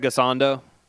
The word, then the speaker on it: Gisondo: Gisondo. – Yeah. Yep. Skyler Gisondo.